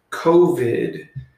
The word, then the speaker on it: COVID: And I think that maybe they would have given me a show, but then they shut everything down, as you know, because of COVID, which rhymes with blow-vid.